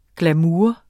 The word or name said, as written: glamour